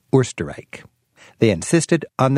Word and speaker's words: Österreich: When an E follows the O, it works like an umlaut, giving the O its correct pronunciation, Österreich.